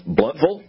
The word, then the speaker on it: Blountville: we'll go to the office up in Blountville. And after doing that for a couple of weeks, I had a judge stop me and go, it's pronounced Bluntville.